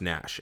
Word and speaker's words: Naish: Carroll Naish, N-A-I-S-H. – Okay. – I guess it's pronounced Nash, and he's actually just an Irishman from New York, so.